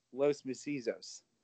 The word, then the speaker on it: Los Macizos: You're talking about Sinclope and Miedo, Los Macizos. – There you go.